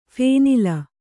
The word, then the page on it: ಫೇನಿಲ: kannada